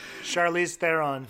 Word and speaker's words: Charlize Theron: I like Char- Char- uh, Charlie Theron. Th- oh my god, I can't say her name. Charlize Theron.